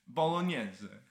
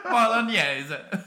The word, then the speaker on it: Bolognese: But gnocchi, and my favorite topping is bologna. I don't know how to pronounce it. I'm always fucking up, and I don't want to mispronounce it when we now have Italian listeners. Bolognese. – Bolognese. I see.